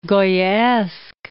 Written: Goyaesque

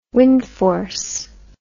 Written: wind force

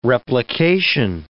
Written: replication

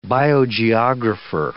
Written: biogeographer

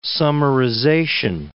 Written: summarization

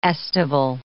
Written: aestival

